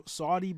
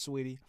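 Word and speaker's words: Saweetie: All right, on to the next, kind of trending topic here is, and I know I'm going to say her name wrong because it reads as Saudi, but I know it, I'm pretty sure it's Sweetie.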